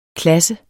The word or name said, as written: klasse